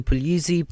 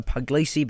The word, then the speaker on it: Puglisi: It's technically Puglisi, but, you know, we go Puglisi because it's simplified, obviously.